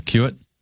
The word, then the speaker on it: Cuit: Tattoo Cuit, or C-U-I-T. How do you say that? You know? – Spell that again. – C-U-I-T. – Yeah, cue it.